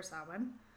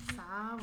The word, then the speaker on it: Samhain: So a lot of this kind of ties into the different rituals done for Samhain. It's like the fourth time I've pronounced it right, and I'm really proud of myself. – Samhain.